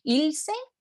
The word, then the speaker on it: Ilse: It's a matter of like side notes, but you recently had Ilse. – Yeah, did I say that right? Yes.